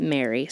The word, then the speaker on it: Marie: And when they found this house, they decided to hire an assistant named, it's spelled Marie, but in interviews, Shania pronounces it Mary.